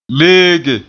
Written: leege

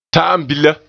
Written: -bila

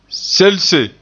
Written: selse

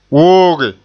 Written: wooge

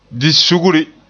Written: di sugri

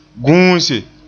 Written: guuse

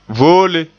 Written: voole

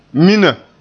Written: mina

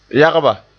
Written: yagba

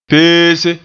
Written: peese

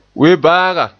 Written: webaaga

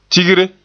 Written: tigre

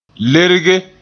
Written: lerge

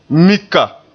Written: mika

